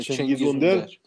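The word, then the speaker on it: Cengiz Ünder: اکثر مثلا چنگیز اوندر، تلفظ صحیح چنگیزه دیگه. – آره چنگیز اوندر اوندر این خیلی تیم‌ها دنبالشن همین الان، یعنی خیلی آره.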